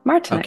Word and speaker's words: Martinek: Yeah, Martinek.